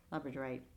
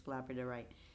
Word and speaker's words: labradorite: Labradorite. I've always say. I know it. I know why I mispronounce it all the time. It's labradorite. I used to always say it labradorite.